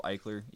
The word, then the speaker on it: Eichler: I mean, my last name is spelled Eichler, E-I-C-H-L-E-R.